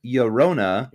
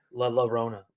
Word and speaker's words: Llorona: It is the Tears of Llorona. – You're saying that wrong. – Am I? – La Llorona. – La Llorona. – It's two L's. That's how you say it in Spanish.